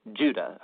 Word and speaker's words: Judah: The letter of Jude, or more accurately, Judah, according to the pronunciation of his name, both in Greek and in Hebrew.